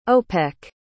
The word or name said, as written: opec